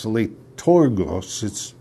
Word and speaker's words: leitourgos: So he's saying that he's doing the word is it's L I can't even pronounce it L E I T O U R G O S liturgos.